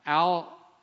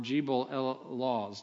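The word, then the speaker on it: Jebel al-Lawz: It's a mountain called Al-Law or Jebel El-Laws, depending on which pronunciation you have.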